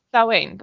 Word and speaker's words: Samhain: It's Samhain.